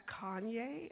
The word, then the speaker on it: Kanye: And they're like, it took them a moment, and they said, are you talking about Kanye? And I was like, oh, that's how you say his name.